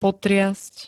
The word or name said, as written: potriasť